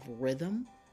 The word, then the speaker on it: rhythm: We have rhythm. You don't even hear the H, but you hear the R. Rhythm. And we have the word thigh. The TH together makes its own unique sound, and we'll talk about that a little later. But rhythm and thigh, the H is silent in those words.